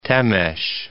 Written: Temes

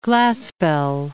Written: Glaspell

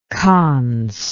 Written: kans

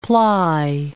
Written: ply

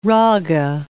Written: raga

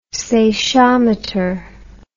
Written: seichometer

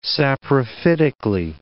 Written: saprophytically

